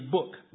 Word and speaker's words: Buuk: Mom would be like, her name is Book, like you read, but it's spelled B-U-U-K.